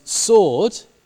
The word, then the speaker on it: sword: Similarly, sword, a sword, becomes sword. It's still written with a W, just like to is still written by it with a W, even though there is no W in the pronunciation.